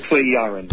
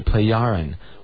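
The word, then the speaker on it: Plejaren: Pleiaran. – Pleiaran, okay. – Yeah, it's pronounced like a Y. – Okay, Pleiaran.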